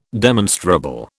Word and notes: demonstrable: British English

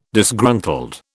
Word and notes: Disgruntled: British English

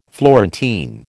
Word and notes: Florentine: American English

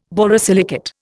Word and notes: borosilicate: Indian

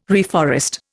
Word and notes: reforest: Indian

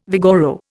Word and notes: vigoro: Indian

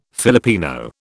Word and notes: Filipino: British English

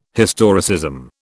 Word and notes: historicism: British English

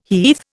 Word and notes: heath: Indian

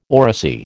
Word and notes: oracy: American English

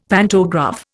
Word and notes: pantograph: Indian

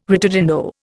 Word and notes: ritardando: Indian

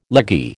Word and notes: leggy: American English

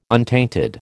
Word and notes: untainted: American English

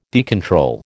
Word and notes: decontrol: American English

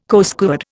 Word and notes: Coastguard: Indian

